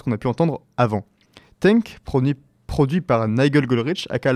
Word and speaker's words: Tangk: Maintenant, il est temps pour nous de voir son compte exactement la même chose, avec le nouvel album de Idols nommé Tank ou Tanka, bon, T-A-N-G-K, que je vais prononcer Tank parce que c'est rigolo.